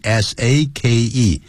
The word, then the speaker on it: sake: 因为这是日文，所以日文的是它就念 Kobe 这样念。好像呢，就像清酒，日本的这个清酒，英文拼成 S A K E，